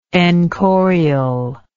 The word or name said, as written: enchorial